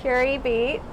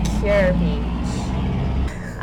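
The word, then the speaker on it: Kure Beach: It is, we are what, live from Curie Beach. We've been saying it wrong the whole time. Yes, it is not Cure Beach.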